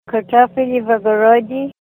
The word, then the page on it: kartofili vavarodi: Ninilchik Russian